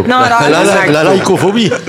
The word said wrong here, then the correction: nyctophobie: Nycto, c'est quoi? – Ça s'écrit N-Y. C-T-O-phobie. – Ah oui, nyctophobie. – Ah ouais, comme nyctophobie.